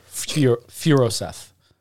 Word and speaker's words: Furoseth: Pronunciation. Andrew Furoseth. Furoseth.